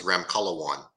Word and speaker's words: Ramkhalawan: Uh, last name is Ramkhalawan. Uh, my name is Dr. Alan Ramkhalawan.